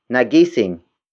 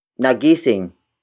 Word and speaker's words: nagising: For example, nagising ang pusa natin. Nagising ang pusa natin. Nagising ang pusa natin. It means our cat woke up. Word for word, it's nagising ang pusa natin. Nagising ang pusa natin.